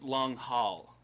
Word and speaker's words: Langhill: Langhill. – It's long haul, actually. – Okay. – There's a lot of silent letters in there.